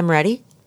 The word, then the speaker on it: Moretti: If you go to Instagram, my account is my name, Mia Moretti, M-I-A. – Yeah, how do you spell it? – M-I-A-M-O-R-E-T-T-I.